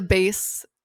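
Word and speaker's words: Béis: The base, is that how you say it? – Yeah. – B-E-I-S, luggage.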